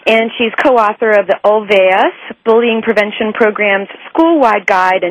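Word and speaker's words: Olweus: And Marlene is the director of the development of the OLVAS. Marlene, did I say that correctly? – You sure did.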